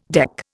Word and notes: deck: Indian

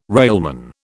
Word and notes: railman: British English

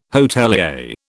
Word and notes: hotelier: British English

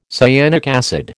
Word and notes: cyanic acid: American English

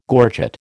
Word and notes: gorget: American English

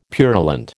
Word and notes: purulent: American English

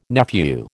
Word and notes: nephew: American English

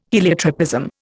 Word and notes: heliotropism: Indian

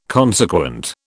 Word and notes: consequent: British English

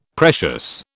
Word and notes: precious: British English

